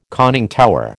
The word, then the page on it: conning tower: American English